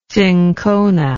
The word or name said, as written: cinchonic